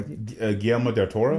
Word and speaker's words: Guillermo del Toro: It's Guillermo del Toro. Oh, anyway. – Guillermo del Toro? – There it is.